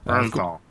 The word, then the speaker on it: Bernthal: Bernthal.